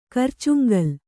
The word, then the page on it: ಕರ್ಚುಂಗಲ್: karcuŋgal